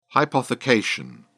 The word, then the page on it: hypothecation: British English